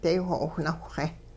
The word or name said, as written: daywho'owh nawhxe